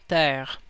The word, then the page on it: world: [wɝəɫd̥]; CA